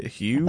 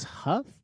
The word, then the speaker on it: Hough: How do you say that name? H O U G Hugh? I thought it was Huff. – Huff? That makes more sense.